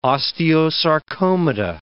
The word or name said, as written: osteosarcomata